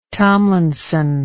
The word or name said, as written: Tomlinson